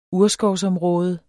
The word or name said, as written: urskovsområde